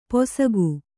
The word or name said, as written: ಪೊಸಗು